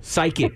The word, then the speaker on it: psychic: Like I've always said, you're a psych dick. – Psychic. It's psychic. Pronounced psychic.